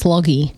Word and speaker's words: Loggy: It's Loggie. – Yeah. L-O-G-G-Y.